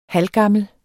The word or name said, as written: HALVGAMMEL